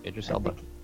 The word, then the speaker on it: Idris Elba: And it's, I don't know how to say it exactly right, but Idris Elba. – Yeah, Idris Elba.